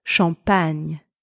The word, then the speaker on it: champagne: So let's stick with the N-I from the word onion in English to pronounce the letters G-N-E in French. So let's combine these two syllables together. We have champ and pagne. Champ, pagne. A bit faster. Champagne, champagne. Et voilà, you have just pronounced champagne the French way.